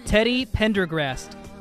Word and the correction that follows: Teddy Pendergrass: Teddy Pendergrass. There's no T at the end. It's not Sean Pendergast.